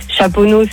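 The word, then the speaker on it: Chaponnay: Chaponneau, c'est ST, tu le prononces pas.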